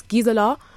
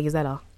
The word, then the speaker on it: Gisela: Yeah, it's not really Gisella, it's Gisela, but because British people can't really say it, so I just say Gisella.